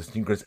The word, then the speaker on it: SNKRS: So if you guys are looking to claim one of these or to get one of these, be sure to download the Sneakers app from either the App Store or even the Google Play Store. It's spelled S-N-K-R-S.